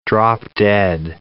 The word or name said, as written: drop-dead